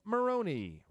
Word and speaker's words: Maroney: So let's meet our NFL outsider, Skip Maroney. Rhymes with baloney.